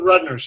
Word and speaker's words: Rudner: Yeah, Sarah Rudner. She was the principal dancer with Twyla Tharp. – Okay, so I'm going to read the quote again. Created in 1983, Heartbeats explores the visceral rhythm of Rudner's heart via an amplification device that musician and multimedia artist Janney attached to the dancer's chest and amplified.